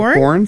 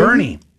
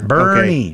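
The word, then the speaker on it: Boerne: Born Texas? – Maybe? Bernie. Bernie. – Oh, that's an ER. – Bernie.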